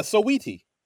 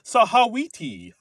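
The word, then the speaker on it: Saweetie: He thinks it's pronounced Sahawiti.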